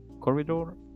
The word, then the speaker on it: Corridor: Mira, hay una gente que yo sigo en YouTube y la recomiendo bastante, que se llaman eh, Corridor, así como Corredor Digital, Corridor Digital. Se escribe Corridor, así con I digital.